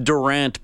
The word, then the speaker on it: Durant: It's pronounced Durant, by the way. Darian Durant. – Darian Durant.